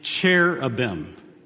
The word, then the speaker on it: cherubim: The correct pronunciation of cherubim, I think, you can ask Tracy Emery expert on pronunciation of biblical words, is cherubim. But I like to think of it as cheerubim.